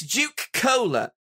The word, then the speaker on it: Juke Cola: Yes, this Juke Cola is actually... – Wait, could you pronounce that for me? – Juke Cola. J-U-K-E-C-O-L-A.